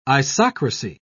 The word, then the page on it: isocracy: British English